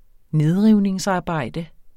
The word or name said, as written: nedrivningsarbejde